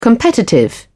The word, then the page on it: competitive: British English